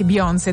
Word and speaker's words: Beyoncé: È il 2003 e Beyoncé, anzi Beyoncé, dai, pronunciamola all'americana, dai, su, facciamo le fighe.